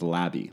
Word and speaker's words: Labi: It's Labby, L-A-B-I.